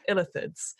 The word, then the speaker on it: Illithids: I'm sure it's going to be a theme throughout all these podcasts where I cannot pronounce things, but I think it's Illithids. That's the legend. – Yeah, that's how I would say it, yeah.